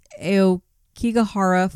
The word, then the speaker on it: Aokigahara: So last on our list for tonight, we have, I believe it's pronounced Aokigahara Forest in Japan.